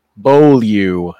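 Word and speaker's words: Beaulieu: and um, Trace Bilu, uh, Bolu, Bolu is how you pronounce yeah. that name.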